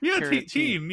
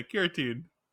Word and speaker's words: keratin: It's care, T-E-N-E. Like, I think that's how you would say keratine. Yeah, keratine.